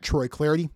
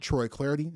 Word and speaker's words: Troy Clardy: Follow me at Troy Clardy. Last name is spelled C-L-A-R-D-Y, at Troy Clardy.